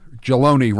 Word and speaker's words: Jaloni: Jaloni rhymes with baloney.